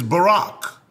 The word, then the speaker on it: Barak: Now, the Hebrew word for blessed is Barak, like Barack Obama, and it's spelled B-A-R-A-K.